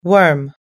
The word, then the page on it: worm: American English